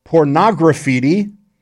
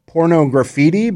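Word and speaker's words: Pornograffitti: Before that, you heard Extreme with Get the Funk Out from their album Pornography, which came out in 1990. And for all my life, I thought that album was called Pornography, but it's supposed to be pronounced Pornography.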